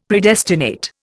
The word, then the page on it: Predestinate: Indian